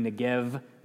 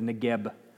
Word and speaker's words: Negev: And if it's silent as it is in this word, it would be Negev instead of Negeb.